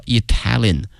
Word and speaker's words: Italian: 意大利这个词啊，同学们要小心了，它的名词和它的形容词呢，这个发音同学们一定要小心了。意大利呢是 Italy 啊，国名。如果说意大利的或意大利人叫 Italian，哎，所以说这个发音还是有区别的。